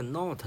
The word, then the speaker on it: knot: 它的英式发音，它的英式发音呢是 not，not。好，这个 not 呢，大家听声音像不像？听的是不是像闹腾啊？对不对？